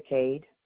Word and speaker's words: Cade: Loretta Cade, C-A-D-E. And Loretta is spelled L-O-R-E-T-T-A.